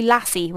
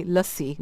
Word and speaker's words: Lassi: Chatti Wali Lassi, not Lassi. Lassi's like the dog.